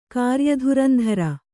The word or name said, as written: ಕಾರ್ಯಧುರಂಧರೆ